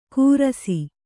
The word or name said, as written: ಕೂರಸಿ